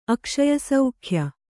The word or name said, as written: ಅಕ್ಷಯಸೌಖ್ಯ